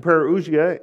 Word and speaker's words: parousia: It's the word parousia. If you're taking notes, it's spelled P-A-R-O-U-S-I-A, parousia.